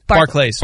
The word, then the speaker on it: Barclays: Is it Barclay? – Barclays. – Barclays. – Like, but is it bank pronounced Barclays Center? – No, Barclays. No, Barclays. Okay.